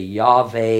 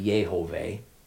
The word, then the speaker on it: Yehovah: And maybe we would say Yahweh, Yahweh, or as I think it ought to be, Yehovah, because I think it's a three-syllable word.